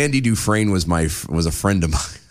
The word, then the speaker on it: Dufresne: It's pronounced Dufresne.